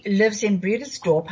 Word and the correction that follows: Bredasdorp: How did you say you pronounced it, uh, Marina? It's Bredasdorp. Bredasdorp, oh, okay.